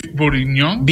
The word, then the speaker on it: bourguignon: Beef bourguignon. – Beef bourguignon.